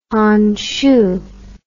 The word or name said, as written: Hanshu